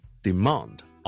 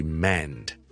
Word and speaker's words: demand: 英式英文我哋系 demand，啊啊啊，demand，跟住 and demand， demand， demand， demand。美式英文咧系会读成咧系 demand， demand， demand。